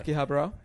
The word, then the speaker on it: Akihabara: Yes, I went to Akihabara. I don't even say it right. – Akihabara? – Yep, you nailed it, bro.